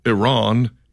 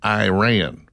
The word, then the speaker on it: Iran: I learned in broadcasting school that it was pronounced Iran, Iraq, things of that nature.